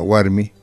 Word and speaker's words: warmi: Por ejemplo, escribí, por ejemplo, la palabra warmi. – Warmi, sí, W-A-R-M-I. – Puedes pronunciar. – Son todas oclusivas simples. Simple. – Puedes escribirla y pronunciarla exactamente.